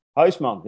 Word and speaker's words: Huisman: Huisman. Yes, that's Huisman.